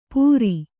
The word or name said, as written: ಪುರಿ